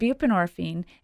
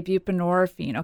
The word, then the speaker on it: buprenorphine: And buprenorphine, and I always say it rhymes with morphine. So if you say buprenorphine, okay.